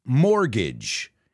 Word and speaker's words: mortgage: Now, I know it has a T in it, but that T is silent. It's mortgage. It rhymes with bridge.